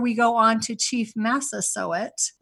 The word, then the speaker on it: Massasoit: For the grandparents and parents and kids that are listening, just say sew it, like sew it up. It's Massasoit. That's an easy way to remember how to pronounce his name.